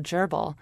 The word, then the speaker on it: Dyirbal: Well, for instance, there are many languages in Australia, a language in particular called Gerbil, which is not spelled like the rodent, but... – How is it spelled? – D-Y-I-R-B-A-L.